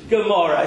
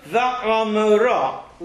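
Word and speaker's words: Va'amorah (עמורה): Gomorrah. It's not Gomorrah. In Hebrew, Sodom, Va'amorah. La'amorah. Not G. It's not Gomorrah. It's La. La. Lion. It's a guttural sound. It comes from the back of the throat.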